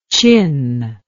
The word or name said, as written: qin